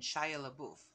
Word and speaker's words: Shia LaBeouf: It's about the tax collector and Shia LaBeouf.